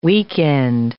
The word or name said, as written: weekend